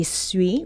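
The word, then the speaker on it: Svoi: So bringing some European-style baked goods to the Central West End with Patisserie Sui, which is spelled S-V-O-I. I had to look that up. So for those who are going to head out there, get the pronunciation of the new shop right.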